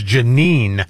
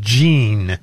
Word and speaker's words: Jeanine: I can't ever say her name properly. It's Jeanine. – Yes. – Not Jean.